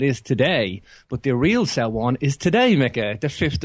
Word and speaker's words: Samhain: You were spot on with Samhain. Depends which Gaelic kingdom you're speaking in.